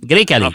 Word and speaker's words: Grecale: Grecale. Grecale. – Faut pas que je mette l'accent sur le A, c'est sur le G. Non, c'est vraiment le, premier.